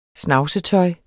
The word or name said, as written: snavsetøj